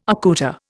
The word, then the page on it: accoutre: Indian